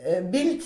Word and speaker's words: Bild: Bild, c'est allemand, une image. – Ça s'écrit B-I-L-D. – B-I-L-D. – D, mais ça se prononce Bild.